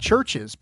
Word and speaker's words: CHVRCHES: And it is this is definitely not in my wheelhouse, but it's a band called Churches, but it's spelled C-H-V-R-C-H-E-S, right?